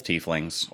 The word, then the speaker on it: tiefling: I have two characters, both tieflings or tieflings, depending on who you are. – Tieflings. It's tieflings. – And anybody who says I'm sorry. Normally, I'm not a stickler for pronunciation because regional differences are a thing. And I definitely say soda and not pop and whatever the fuck else. – Water. – Caramel versus caramel. But it's tiefling.